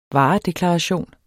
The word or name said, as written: Varedeklaration